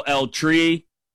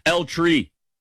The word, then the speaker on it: El Tri: where I know El Tri. I said that as white as possible. El Tri? That's not a place where you roll your R. El Tri.